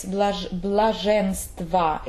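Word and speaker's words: блаженства: Yes, blazhenstva, it's spelled O in the end, but it pronounces A.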